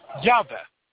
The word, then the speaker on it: Yahweh: Overwhelmingly, scholar opinion holds that the Tetragrammaton was in Moses' time pronounced Yahweh.